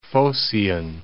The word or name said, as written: Phocian